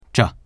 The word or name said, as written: zhe